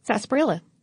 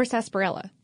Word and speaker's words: sarsaparilla: Is that how you pronounce it? – Sarsaparilla. – It does not look like that. – Or sarsaparilla. – Sarsaparilla. – You can say it either way.